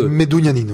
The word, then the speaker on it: Medunjanin: Est-ce que je ne sais pas si je prononce bien comment on dit c'est Medunjanin, en fait. Medunjanin. On dit le J, c'est comme I, en fait.